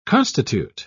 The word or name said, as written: constitute